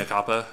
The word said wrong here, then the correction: mea culpa: Mea culpa? – Yeah, whatever. – Okay. – You know what I mean. – Mea culpa is actually how you say it if you're using layman tongue.